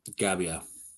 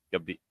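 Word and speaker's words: Gabbia: You're supposed to say the double B. – Gabia? Gabia? – Yeah. Gabia. I don't know. I know you say the double B. That's it. – Yeah. – You're putting emphasis on the A, and that's wrong.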